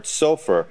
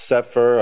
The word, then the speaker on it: sofer: From the word sofer, this word is sometimes pronounced sefer or safer because of the vowel points.